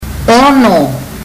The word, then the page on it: ono: fiji